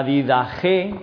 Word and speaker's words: Didache: La Didache, o Didache escrito, pero se pronuncia Didache, Didache Apostolorum, que se traduce como la enseñanza de los apóstoles.